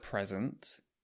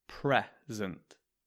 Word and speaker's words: present: So in the word present, the first syllable, that's the first sound, is stressed. We say present, present. There's another word that is spelt the same, but the stress is in a different place, and that is present, present.